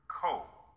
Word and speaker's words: Ko: Ko, as if it were spelled K-O, a single syllable.